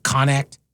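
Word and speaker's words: Qonnect: It's spelled with a Q, but no U. Q-O-N-N-E-C-T. Connect, whatever. Connect.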